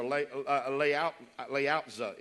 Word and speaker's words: Lao Tzu: The old Chinese philosopher, Lao Tzu is how you pronounce it.